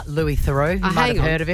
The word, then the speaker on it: Louis Theroux: Louis Theroux.